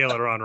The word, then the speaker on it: aileron: It's an aileron roll. I think that's how you pronounce it. – Yes, the technical term would be aileron roll.